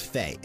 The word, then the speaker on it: Fe: and I believe it's pronounced Fe. It's F-E. So from a game called Fe, this is Hobbit.